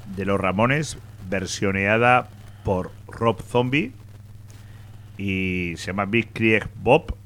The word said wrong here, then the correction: Blitzkrieg Bop: Blitzkrieg Bop.